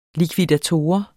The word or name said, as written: likvidator